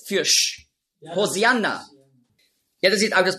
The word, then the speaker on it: Hosianna: Hosianna, ja, ist einfach die griechische Aussprache von Hoshi'anna. Hosianna, das ist die griechische Aussprache. Weil die Griechen, die haben im Alphabet kein Sch-Laut. Nurz. Die Hebräer schon. Auf Hebräisch sagen Hoshi'anna. Aber Griechen können das nicht.